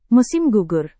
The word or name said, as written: musim gugur